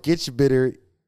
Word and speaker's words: gitch bitter: Gitch bitter. – Like bitter? Like you're bitter? – Yeah.